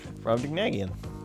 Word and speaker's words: Brobdingnagian: Brobdingnagian. Mm-hmm. Does that sound like I'm saying it right? – Yeah. – Brobdingnagian? – Brobdingnagian.